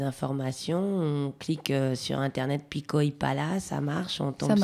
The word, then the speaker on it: Pico y Pala: Pico y Pala. – Donc P-I-C-O-Y-P-A-L-A, donc un peu comme ça se prononce. – Voilà, c'est l'avantage de l'espagnol.